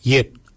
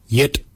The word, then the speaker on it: yed: Nanak is spelled N-A-N-G-A-G. Nanak, nanak is your neck. Chin, we say yet, yet for chin. Yet is spelled Y-E-D.